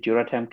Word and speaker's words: Duratem: So that's why we kind of named it Duratem, kind of rhyme with Ultem.